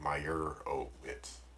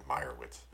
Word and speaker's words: Meyerowitz: They didn't really accentuate the O. So it wasn't like Meyerowitz. They just said Meyerowitz. – Meyerowitz. I think it's Meyerowitz.